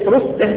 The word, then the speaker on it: رسه: ذكر ابن أبي حاتم عن أبيه عن رسه الأصبهاني رسه من غير بالهاء بالتسكين مربوطة يعني بالتسكين رسه زي منزه واره ماجه بالهاء دي كلها بالهاء الساكنة يعني هي كلها أسماء عجمية أصلا نطقها هكذا.